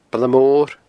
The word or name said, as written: Baile Mor